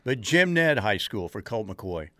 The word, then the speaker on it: Jim Ned: Jim Ned, yeah. – And I always thought they pronounced it Jim and Ed High School.